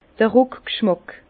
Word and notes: d'r Rùckg'schmàck: Bas-Rhin; Herrlisheim

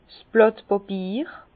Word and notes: 's Blàt Bàpier: Bas-Rhin; Herrlisheim

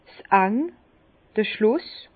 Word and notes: 's End: Bas-Rhin; Herrlisheim

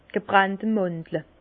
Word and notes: d' gebrannte Mandle: Bas Rhin; Herrlisheim

